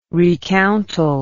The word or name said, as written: recountal